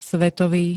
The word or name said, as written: svetový